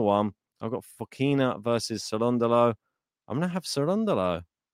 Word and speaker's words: Cerundolo: Let's check to see how to say his name properly. – Hi, I am Francisco Cerundolo, and you are listening to the Game to Lab podcast. There you go. Cerundolo. Okay. uh, as long as we, we've always got that just to confirm how to say his name correctly.